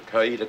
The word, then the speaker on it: Coweta: The movie was called A Murder in Well, listen, on paper, it's Coweta, C-O-W-E-T-A. That's not how they say it. Let's hear how Johnny Cash says it. – I'm Sheriff Lamar Potts from over in Cayuga County.